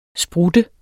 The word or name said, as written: sprut